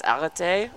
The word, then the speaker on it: arrête: So the full form of the verb you're looking for here is arrête, which yeah.